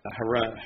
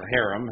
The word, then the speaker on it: harem: If the king had a harem, really is how you pronounce it, or harem, as we pronounce it, you didn't want the dude running the place getting in, you know, in bed with all the harem.